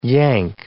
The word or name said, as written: yank